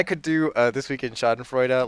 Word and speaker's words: Schadenfreude: Is that how you pronounce it? – Schadenfreude.